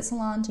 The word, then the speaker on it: Solange: Is it Solange? – Yeah.